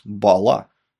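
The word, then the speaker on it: Balat: Его зовут Альфонс Бала с ударением на последний слог.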